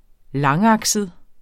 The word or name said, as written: langakset